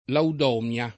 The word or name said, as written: Laudomia